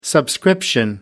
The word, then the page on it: subscription: American English